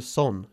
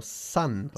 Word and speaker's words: son: No se pronuncia son, sino son.